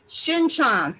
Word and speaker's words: Shinchan: Okay, I said his name wrong. It's Shinchan. Shin Shin Shinchan. – How do you spell it? – S H I N. And Chan is C H A N.